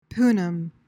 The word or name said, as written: punim